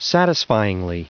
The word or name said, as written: satisfyingly